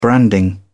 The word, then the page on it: branding: British English